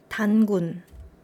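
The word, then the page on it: 단군: Dangun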